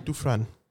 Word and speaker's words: Dufresne: Sylvain Dufresne. – There's an S. – It's French. – But there's an S. – Yeah, but it's French. They choose not to pronounce some letters.